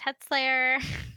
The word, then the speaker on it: Huttsleia: um H U T T S L E I A, it's pronounced Hetslayer.